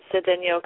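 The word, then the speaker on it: Cedeño: It's Cedeno, because I saw that little accent over the end.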